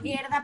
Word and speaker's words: Gerda: Me permito decir una anécdota porque me cuesta decir Gerda, uno tiende a leer Gerda, pero como bien me lo recordó nuestra recuerda la palabra gorda ponle la E y así pronunciarás Gerda perfecto.